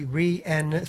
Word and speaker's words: Rhiannon: Rhiannon, so it needs to be Rhiannon, three syllables. But why that three syllables?